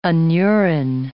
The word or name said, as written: anuran